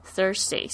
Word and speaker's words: Thursday: Thursday 是星期四，记得这个 th 的发音是这个 th 加上 ur 这个卷舌音，Thursday of November 哦，那讲到这个星期的话，我们还要学这个月份呢、哦。